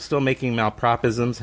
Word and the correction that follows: malapropism: How do you pronounce that? – Yeah, malapropism.